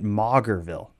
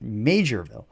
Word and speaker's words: Maugerville: We had mispronounced that, and I got quite a few emails because it's spelled like M-A-U-G-H-E-R or something. So I was pronouncing it Maugerville, but they actually pronounced it Majorville.